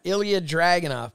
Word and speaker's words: Ilya Dragunov: Ilya Dragunov.